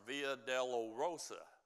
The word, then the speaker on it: Via Dolorosa: They call that, Marks, Via Dolorosa. Did I say that right, Carol? I said that right.